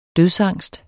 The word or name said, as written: dødsangst